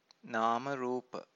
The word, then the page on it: namarupa: Pali